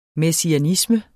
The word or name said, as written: messianisme